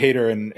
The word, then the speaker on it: Haider: You mentioned the Jörg Haider in Austria and some of the others.